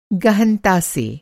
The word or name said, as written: गहनता